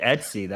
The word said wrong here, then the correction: ETC: That's interesting. That's incorrect. What? It's ETC, right? – Okay, sure.